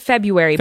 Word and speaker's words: February: Everyone says it February, but it is spelled February.